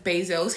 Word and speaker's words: Bezos: He's am I saying his name right? – Yeah, Jeff Bezos.